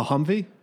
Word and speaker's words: Humvee: A Humvee? – Yeah, just as HMMW.